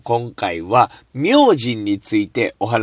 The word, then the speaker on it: 明神: 明神って何と思った方たくさんいらっしゃると思うんですが、字で書くとですね、名前の名という字ですね。えー、それに神様と書いて、明神と読むわけでございます。